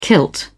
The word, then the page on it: kilt: British English